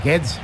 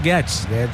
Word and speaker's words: Guedes: Sí, es Guedes. – Gonzalo Guedes. Parecía alemán, más que portugués.